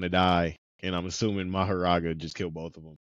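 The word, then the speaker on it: Mahoraga: So he called in Maguraga. I feel like I'm saying that wrong. – I think it's Maharaga. – Maharaga. – It's M-A-H-O-R-A-G-A. Yeah, so he called out Maharaga, and he was like, all right, we're going to have to do this ritual together.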